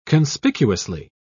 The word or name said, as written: conspicuously